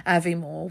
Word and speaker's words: Aviemore: exactly how to say it, Aviemore, which it is the way it's spelled.